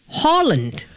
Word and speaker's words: Haaland: The very native way of pronouncing his name is Erling Haaland. So when you see a double A in a Norwegian name or in the Nordic language, it's actually kind of pronounced as an O. – Oh. – So it's like an A with an O on the top.